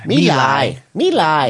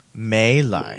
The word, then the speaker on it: My Lai: That's how it's pronounced, because, and I can prove it, because there's a pronouncer. Americans always say foreign names wrong, but there's a pronouncer here that's being read by a Vietnamese person. So listen. – May Lai. – It sounds like May to me.